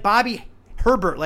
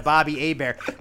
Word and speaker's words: Bobby Hebert: It's like Bobby Herbert, like I used to call him as a little kid, instead of Bobby Hebert,